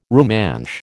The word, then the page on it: Rumansh: American English